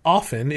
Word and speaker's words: often: When I learned the word often, it was with a silent T.